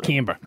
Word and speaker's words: Canberra: That's how they say it. Canberra.